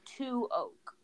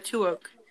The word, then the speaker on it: TOOAK: But the abbreviation is T-O-O-A-K. And I pronounce that Two Oak. – Yeah, Two Oak. – Like we love T-O-O-A-K. Two Oak.